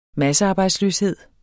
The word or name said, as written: massearbejdsløshed